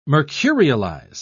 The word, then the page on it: mercurialize: British English